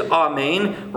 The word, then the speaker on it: amen: In fact, the Hebrew pronunciation is amen, rhyming with train, and doesn't sound like men.